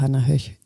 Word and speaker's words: Hannah Höch: Werde ich auf jeden Fall mal reinhören und Respekt an dich, dass du Hannah Höch hören möchtest.